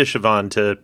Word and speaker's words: Siobhan: S-I-O-B-H-A-N. – Yes, that's it. That's exactly it. It looks like Shibuhan. – Yeah, Siobhan.